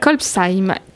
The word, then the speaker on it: Kolbsheim: Et qui s'appelle ce village Kolbeich. Ah non, voilà, j'ai raté mon coup. Je ne vais pas le refaire. Hein. – Kolbsheim. – Kolbsheim, qui s'écrit K-O-L-B-S-H-E-I-M, qui se trouve en Alsace, et qu'on va inviter nos amis auditeurs à aller aussi découvrir.